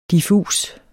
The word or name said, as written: diffus